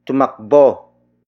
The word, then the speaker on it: tumakbo: As a direction indicator, another example would be tumakbo sa kanila ang dalaga. Tumakbo sa kanila ang dalaga. Tumakbo sa kanila ang dalaga. It means the young lady ran to them. Word for word, it's tumakbo sa kanila ang dalaga.